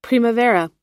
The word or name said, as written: primavera